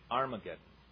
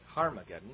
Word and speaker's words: Armageddon: Is it really Armageddon? Well, you know that it's really made up of the Hebrew word har. We should pronounce it with an H on the beginning of it, Harmageddon.